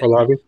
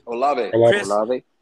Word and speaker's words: Olave: Oh, how you say it? – Olave. – Olave. – Yeah, I mean, Olave.